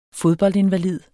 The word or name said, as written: fodboldinvalid